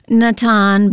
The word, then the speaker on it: Natan: Well, the main character, do you pronounce it Natan? – I've used the names from the Hebrew scriptures, so Natan, but Natan. In the King James Bible, he's Nathan.